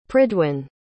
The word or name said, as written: pridwyn